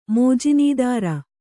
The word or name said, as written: ಮೋಜಿನೀದಾರ